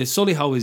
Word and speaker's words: Solihull: Yeah, Solihull is incredible in that it's a place that manages to get three syllables into a one-syllable word. Solihull.